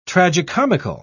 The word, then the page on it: tragicomical: British English